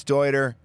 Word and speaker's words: Deuter: Deuter. Dude, it's Deuter. We know how to say it.